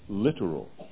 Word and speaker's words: littoral: And for you young people, a homonym is a word that sounds like another word but has a different meaning. It's called littoral. It's spelled littoral. It's not literal like we say the word literally.